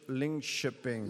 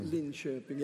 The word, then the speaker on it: Linköping: from Linköp, Linköping. – Linköping, ja.